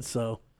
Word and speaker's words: Tso: You mean General Tso? – Yeah. – What did you just say? – I said so. – I don't know, man. – Silent T, bruh.